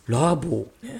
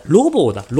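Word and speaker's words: 路傍: ラボーね。はあ、ラボーの藤という、ごめんなさい。ロボーだ。ロボー。ロボー、これメールだとちょっと読みづらかったけど、ちゃんと見たらわかるわ。ロカタの、路地とかのロに、傍らっていう字だ。これロボーって読むんですね。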